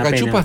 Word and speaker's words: cachupa: Che, y la cachupa está buena. – Está buena. – ¿Cómo se dice? – Cachupa. – Cachupa. – Sí, pero es cachupa, sí.